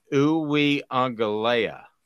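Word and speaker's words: Uiagalelei: Daniel says it's Uweungalea. Uweungalea. Actually, I think he's right. Is that better? Uweungalea? – Pretty sure that's how it's pronounced.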